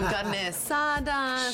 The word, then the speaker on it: carne asada: I had several margaritas yesterday with some, some carne asada, some chips and salsa. – She said it right.